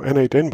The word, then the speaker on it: n8n: And I think the official way to pronounce it is now n8n, but it was Node Mation not too long ago. Is that right? – That's correct. Like I actually made the change in our documentation and the repositories because we were like, okay, n8n is quicker to speak as well. And I think it was confusing for a lot of people. Should I call it n8n? Should we call it Node Mission? So we just made it explicit, just like pronounced n8n.